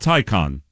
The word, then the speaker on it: Taycan: What is a TAYCAN? – TAYCAN. – It's pronounced Tycon. – Tycon. Okay, Tycon. As in Ticonderoga. – Ticonderoga. – Right. Tycon.